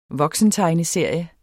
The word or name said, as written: voksentegneserie